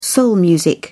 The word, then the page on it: soul music: British English